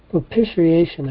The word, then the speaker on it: propitiation: Propitiation, I believe. Let me see. – Propitiation.